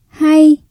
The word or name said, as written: hay